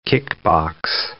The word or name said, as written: kickbox